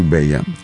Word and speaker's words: Beya: And we named her Beya, but we spell her name B-E-Y-A because if we spelled it in Spanish, B-E-L-L-A, people would most likely pronounce it Bella. We want to make sure they pronounce it Beya. Beautiful, B-E-Y-A.